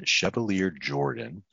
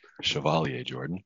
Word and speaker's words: Chevalier-Jordan: Our guest today is Brian Chevalier-Jordan. I hope that I pronounced that right. – Almost. It's Chevalier-Jordan. – Chevalier-Jordan.